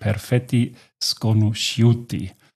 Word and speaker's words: perfetti sconosciuti: So steht es hier, wird ausgesprochen perfetti sconosciuti.